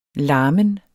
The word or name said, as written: larmen